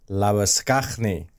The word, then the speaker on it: Labuschagne: In particular, Warner and Labuschagne.